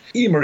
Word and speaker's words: Eimear: Emer is an Irish girl's name, pronounced E-E-M-E-R, and we think the British population may have struggled with the pronunciation too, because of course it's spelled E-I-M-E-A-R.